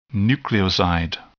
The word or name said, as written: nucleoside